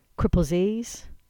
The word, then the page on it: Cripple's Ease: English